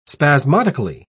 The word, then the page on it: spasmodically: British English